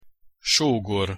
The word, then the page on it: sogor: Hungarian